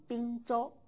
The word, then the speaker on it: 并州: 那么在此地并州啊，读成滨州。那么一般语词我们就读成地，但是在此地地名读成滨。